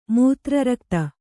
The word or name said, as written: ಮೂತ್ರರಕ್ತ